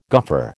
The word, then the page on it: goffer: American English